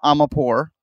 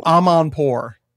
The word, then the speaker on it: Amanpour: How do you pronounce it? – Christina Amanpour. – Also not right. – Okay. Who's that? – There's an N in there. – I'm gonna go pour myself a Zoa. – Omnivore? – No. Omnivore. She eats everything, bro. – No. – Amanpour. – Amanpour. – Yeah.